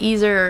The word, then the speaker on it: Ezer: E-Z-E-R. – Okay. – People pronounce it Ezer or Ezer.